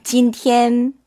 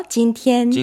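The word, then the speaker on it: 진티엔 (今天): 그게 중국어로는 일을 쓰지 않고 하늘천자를 써요. – 하늘천. – 그래서 금천. – 금천. – 진티엔. – 진티엔. – 물론 우리 문어체에서는 금 이를 쓰기도 해요. 근데 발음이 어려워서 진티엔. – 진티엔. 하늘 천자를 쓰기 때문에 하늘은 위에 있잖아요. 일성. 진티엔.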